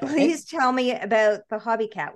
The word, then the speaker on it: Hobie: Well, it's pronounced Hobie cat. – Oh, Hobie. Okay, yep. There we go. – Not a Hobie cat. Not like, you know, knitting socks with your cat.